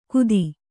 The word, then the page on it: ಕುದಿ: kudi; kannada